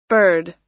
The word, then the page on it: bird: American English